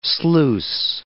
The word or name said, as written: sluice